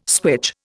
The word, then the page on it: switch: Indian